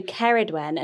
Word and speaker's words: Ceridwen: But Ceridwen. That wen sound is what she's liking from the Welsh, isn't exactly. – it? Whereas the lady before mentioned Seren, which is Welsh. – So Ceridwen, and she likes this Gwyneth.